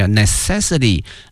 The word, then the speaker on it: necessity: 我们学过它的使用词哈，非常的熟悉 necessary，但是呢变成了名词哈，它的发音呢多少有些变化，叫 necessity，我们可以说空气、水哈、阳光是我们生活的必须，我们可以说 sunshines, fresh air and waters are necessary for our life。